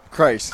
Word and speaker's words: Crise: Did I say that right? – Christ. – Christ. – Yeah. – C-R-I-S-E. Logan Christ.